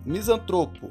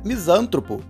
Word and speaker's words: misantropo: Fala pessoal, misantropo. Misantropo indica aquela pessoa que tem aversão à convivência humana, à convivência em sociedade. É uma palavra paroxítona, logo a pronúncia misântropo é inadequada.